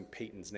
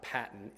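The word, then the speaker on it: Paton: I love John Piper, but he has discipled us poorly in pronouncing Payton's name. It's not Patton, it's Payton. Payton is a Scotsman, and the Scots rebuked me when I gave a talk one time about John Patton. Patton's the general, Payton's the missionary.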